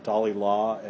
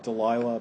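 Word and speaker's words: Dalila: Dalilah, as we pronounce her name in Samson, not Delilah, but Dalilah, seduces Samson.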